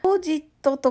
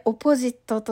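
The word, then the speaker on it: opposite: このオポジットと書くんですけれども発音がねまたねオポジットという発音ではないんですよね。そこがちょっと大変なんですけれども、はい、オポジットと言います。おがね、おにアクセントがつくんですけれども、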